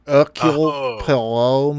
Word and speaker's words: Hercule Poirot: This is a Hercule Poirot movie. As you can tell, I say it correctly.